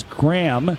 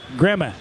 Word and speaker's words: Gramme: And even though it's spelled G-R-A-M-M-E, and many thought his last name was Graham, it is, long-range shot redirected by Strammel, didn't get on goal. It is Gramma, like Graham, eh.